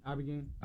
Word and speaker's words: Ibogaine: Is it Ibogaine? Am I saying that right? – Ibogaine. – Ibogaine. Mm-hmm.